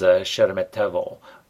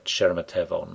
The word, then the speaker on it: Sheremetyevo: There's the Domo, or Domodedovo, D-O-M-O-D-E-D-O-V-O, and there's Sheremetyevo, S-H-E-R-E-M-E-T-Y-E-V-O.